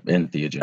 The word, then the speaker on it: entheogens: is it entheogens? – Yeah, entheogens. – That's how you say it.